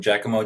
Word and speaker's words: Giacomo: Yes, I'm Giacomo Gianniotti.